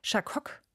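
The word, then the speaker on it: Chacok: Nach ihrem Modedesign-Studium haben sie bei dem französischen Label Chacoc spricht man so aus, richtig? Ja, richtig. Mhm.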